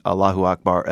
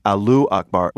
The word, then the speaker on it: Allahu Akbar: And I had to laugh again yesterday when the internet spotted several TV newscasters reporting on the New York attack, mispronouncing Allahu Akbar as Alu Akbar, which translates to what? – Potatoes are great.